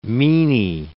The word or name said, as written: meanie